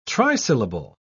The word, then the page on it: trisyllable: British English